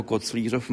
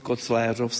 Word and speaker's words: Koclířov: A také jsem si všiml, že tato obec Koclérov nebo Koclířov má vlastně dvojí výslovnost. A já se zeptám pana badatele Hladkého, jak to tedy je. – Původně se to německý za rakouských dob, že jo, císařů se používalo Kecesdorf pro tuto oblast a dole byla, co je Fatima, neboli Koclířov, se používal název správný Kecesdorf Schengentau. – A v současné době se ustálila tedy výslovnost Koclérov s E.